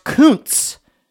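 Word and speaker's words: Kuntz: I made sure to look it up, and I looked it up, and it's pronounced Koontz. Rusty Koontz, not Rusty Koontz, which is how it's spelled and how you would think it was spelled.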